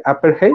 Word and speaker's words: Apartheid: de corríjanme porque tal no lo puedo pronunciar bien, Upper, Apartheid. Apartheid. Apartheid.